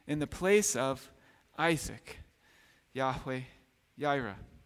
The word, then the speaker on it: Yahweh Yireh: You hear the name in that chapter of Yahweh Yireh, which sometimes gets pronounced Jehovah Jireh in our culture, Yahweh Yireh.